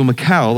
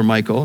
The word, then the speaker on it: Michal: So Michal, or Michael, depending on how you want to pronounce that, let David down through the window and he fled away and escaped.